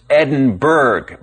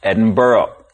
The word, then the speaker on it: Edinburgh: Like the name of Scotland's capital city, it looks like it should be pronounced Edinburgh, but it's actually pronounced Edinburgh.